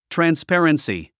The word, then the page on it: transparency: trænspéərənsi; トランスペァレンスィ